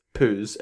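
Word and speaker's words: Pauz: And it's P-A-U-Z, rhymes with ooze. So obviously it's Pooh's, everybody, and we can kind of stop that argument now.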